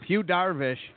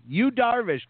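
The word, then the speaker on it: Yu Darvish: Why do you pronounce it what? – U with an H? It's Y. – U. You Darvish, Clark. You Darvish.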